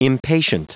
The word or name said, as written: impatient